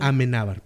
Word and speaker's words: Amenábar: Alejandro Almenábar, el que, el que dirigió. – Amenábar, perdón.